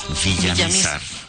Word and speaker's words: Villamizar: Porque el nombre se escribe sin acento, habría que decir Jorge Villamizar.